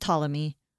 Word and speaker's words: Ptolemy: But there's another region centered in Egypt, and that was controlled by a general named Ptolemy. – Mm-hmm. – Actually, just Ptolemy. I don't know why. I don't know how a silent P can exist.